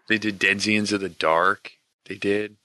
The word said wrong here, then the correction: denizen: Say that word again. – Denzians. – Denzians. – You have never played a role-playing game. – Why? How would you say it? – It's Denizen.